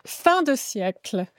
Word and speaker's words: fin de siècle: How do you pronounce it? – Fin de siècle. – Fin de siècle.